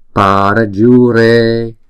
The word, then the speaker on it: Parajure: Chữ pa, chữ ju và chữ re thì chúng ta đọc mỗi âm là 2 nhịp, còn chữ ra là 1 nhịp ha. Parajure. Chữ J này chúng ta đọc là J, J. Jure, parajure. Jure, jure, re. Chữ E cho dù không có dấu gạch ngang trên đầu nhưng chúng ta vẫn đọc 2 nhịp.